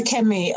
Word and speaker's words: Kemi: I prefer Kemi over Olu Kemi because people can't.